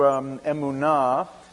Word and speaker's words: emunah: The Hebrew emunah is faith, and it's being pronounced in a variant form, amuna,